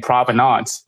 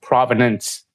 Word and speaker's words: Provenance: I keep hearing people saying Provenance. It's actually, I'm pretty sure it's pronounced Provenance. So the A is... pronounced with an E.